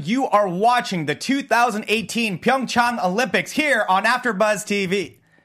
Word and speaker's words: Pyeongchang: No, see, Pyeongchang.